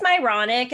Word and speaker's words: Myronic: I say It's it's myronic. It's like ironic with an M, and then I would add, "There's a lot of irony in my life," and I get a little chuckle.